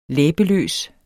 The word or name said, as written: læbeløs